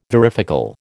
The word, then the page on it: varifocal: American English